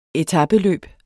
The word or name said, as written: etapeløb